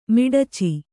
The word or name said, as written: ಮಿಡಚಿ